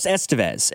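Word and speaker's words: Estévez: Like, when the Royals picked up Carlos Estevez, I read the last name and immediately thought of Emilio Estevez. And so I was pronouncing it Carlos Estevez. And they're like, no, it's Estevez.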